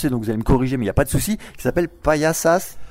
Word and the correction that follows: Payasos: Payasos, qui veut dire paillasse en français, donc les paillasses, c'est aussi des clowns.